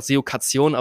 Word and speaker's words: SEOcation: Oder ich habe damals gedacht SEOcation, aber es wird ja englisch ausgesprochen.